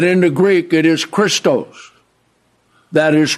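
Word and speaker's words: Christos (Χριστός): That doesn't mean we're not pronouncing the name of Christ correctly, because in the Greek, the name of Christ could be pronounced Christos, Christou, Christo, and Christon.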